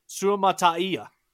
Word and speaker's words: Suamataia: going to try and pronounce his last name, Suamataia. And I think I did that right.